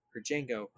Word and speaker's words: Django: And I saw Django today. And yeah, the D is silent.